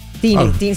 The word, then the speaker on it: Tini: Es Tiny. – Ajá, es Tiny. Ajá. – ¿Es Tiny? – Sí, pero le dicen Tini también. Ajá. Pero canta demasiado bien. – ¿Tiny? – Tini, se escribe Tini.